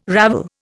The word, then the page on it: ravel: Indian